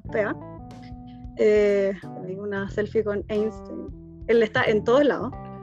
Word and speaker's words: Einstein: Visité el Museo de Einstein, Ah, bueno, ahora sé que se pronuncia Einstein.